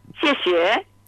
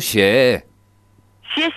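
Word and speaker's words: Xie xie (谢谢): Xie xie. Xie es, se escribe X-I-E. – Ah, xie xie. Más o menos. – Xie xie, ajá, y siempre rapidito. Xie xie. – Xie xie. Ah, apurado. Como apurado.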